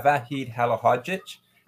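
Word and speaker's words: Vahid Halilhodžić: Well, we begin with manager Vahid Halil Hodit. I'm not sure if I'm saying that right. – Yeah, Vahid Halahodic.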